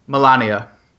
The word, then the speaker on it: Melania: Is it Melania? – Melania. – Melania. Melania Trump.